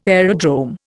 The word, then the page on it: aerodrome: Indian